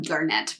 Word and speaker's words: Garnett: Garnett is how he pronounces his name, but they mention that it's a garnet.